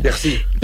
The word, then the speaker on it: Piercy: Mujer al borde del tiempo, de Marge Piercy, o Piercy, no sé muy bien cómo se pronuncia. – Piercy. Sí, muy rápido.